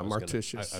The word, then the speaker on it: Martishius: Yeah, Martishius.